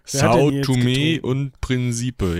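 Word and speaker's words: São Tomé und Príncipe: Und du hast vorgeschlagen, dass wir uns dann doch mal die Insel Sao Tome und Prinzipe angucken sollen. Ich hoffe, ich habe das richtig ausgeschlossen. Äh, ausgeschlossen. Hi, hi, aus, ausgesprochen. – Sao Tome und Prinzipe, ja, die, die ähm, Lautschrift gibt dir recht.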